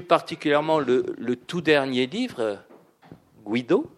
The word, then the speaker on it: Guido: Je ne sais pas si je prononce comme il, comme euh, il le faut. Guido, c'est ça Non, c'est bien, n'est pas le dernier. – Pardon Vous prononcez bien, mais c'est pas le dernier.